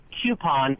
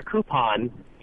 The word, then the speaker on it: coupon: I say coupon, but I am pretty sure that if you look it up in your dictionary, and this eliminates an awful lot of pronunciation problems, you're going to find out that it says coupon first and coupon second.